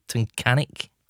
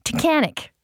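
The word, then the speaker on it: Tunkhannock: It says it rhymes with mechanic and then it looks like Tuncanuck, so it must be Tuncanic. – Hold on, it rhymes with mechanic. Tuncanic. Tuncanic. I think that's how you say it.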